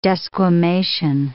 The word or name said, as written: desquamation